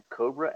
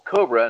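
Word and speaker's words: Kobra: If you have a chance to go check that out, it's a band called Cobra, and it's spelled with a K, Cobra and the Lotus.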